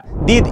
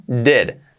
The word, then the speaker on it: did: Para pronunciarlo, cuidado que no sea did, sería dead. Dead. La I casi, casi como una, ¿eh? Dead. Dead. ¿Vale? La tenemos dead. Ya no diríais did, será dead.